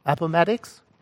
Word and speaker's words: Appomattox: Is that how you say it? Appomattox? Yeah.